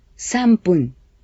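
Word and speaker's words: sanpun (さんぷん): Los minutos se pronuncian pun cuando el número que viene antes es 1, 3, 6, 8 o 10. De modo que un minuto se dice ippun y tres minutos es sanpun.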